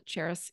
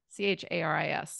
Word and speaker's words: Charis: It's spelled like the city, spelled and pronounced like the city Paris to help everyone understand, but with a CH, C H A R I S.